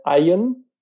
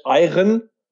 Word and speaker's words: Iron Sky: Nein, ich meine natürlich nicht Eye im Sky, sondern ich meine Eien, so wie das äh, oft ausgesprochen wird im Deutschen falsch. Iron, Iron Sky.